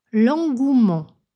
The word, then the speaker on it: engouement: Autre terme contenant un E muet, l'engouement. Un engouement s'écrit E-N-G-O-U-E-M-E-N-T et signifie un grand enthousiasme.